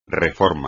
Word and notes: reforma: espagnol

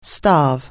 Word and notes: starve: anglais

